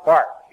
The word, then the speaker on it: Barth: Barth. His name rhymes with heart.